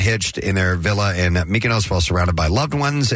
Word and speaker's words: Mykonos: The couple told People in a joint statement, we chose to celebrate our love by getting married at our villa in Mykonos. – Mykonos.